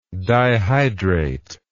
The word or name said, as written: dihydrate